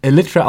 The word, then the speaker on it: Elytra: Also man spricht den Bandnamen Elytra aus, wie das englische Wort Elytra auch.